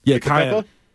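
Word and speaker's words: Kiyan: Kyan. It's not spelled the same way, but it's pronounced the same way. – Okay. – I love Kyan. – I love a good cayenne pepper.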